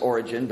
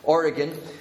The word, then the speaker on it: Origen: Usually a lot of people pronounce it Origen, but it's a hard G. Origen, who actually made himself a eunuch.